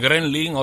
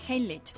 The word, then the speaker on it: Heimlich: algunos dicen Gremlin, otros Frenfen, otros lo inventan, y se dice correctamente. – Bueno, pues se dice maniobra de Heimlich.